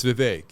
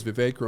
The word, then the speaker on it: Vivek: It's Vivek. – Vivek. – Yeah, he says Vivek rhymes with cake.